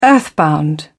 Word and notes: earthbound: British English

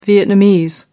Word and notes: vietnamese: American English

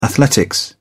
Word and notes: athletics: British English

